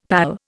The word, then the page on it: pal: Indian